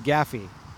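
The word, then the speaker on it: Gaderffii: I want to say they're spelled Gadurfee, but they're pronounced Gaffy.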